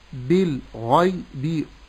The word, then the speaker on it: بالغيب: ياء ساكنة ما قبلها مفتوح، هذا لين وتعرضنا له من قبل، ثم بعد ذلك باء مكسورة أقول بي الباء الأخيرة، يبقى النطق السليم لهذه الكلمة أقول بالغيب، وأفخم الغين لا أقول بالغيب ولكن أقول بالغيب. – بالغيب تفخيم الغين.